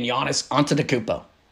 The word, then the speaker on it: Giannis Antetokounmpo: Can they shut down arguably the most dominant player the NBA has to offer today in Giannis Antetokounmpo? And look at that pronunciation. I have it down.